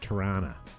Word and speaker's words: Toronto: For those of you that watch, we are from Canada, and we don't pronounce it right, but it's actually pronounced Tirana.